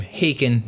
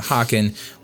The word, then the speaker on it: Haken: I have since come to learn that they pronounce their name Haken, not Haken, which is fine, whatever.